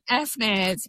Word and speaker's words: fNIRS: Firstly, well done on your pronunciation. FNIRS, yes.